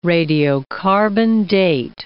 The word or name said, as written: radiocarbon-date